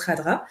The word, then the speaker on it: Khadra: Donc tous les livres qu'il écrit sont sous le nom Yasmina, ça s'écrit Kadra mais ça se prononce Radra.